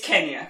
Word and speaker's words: Kenya: But yeah, it's Kenya. – I didn't even know that was racist. – It is the colonial pronunciation of the nation. So it's like still saying Peking instead of Beijing.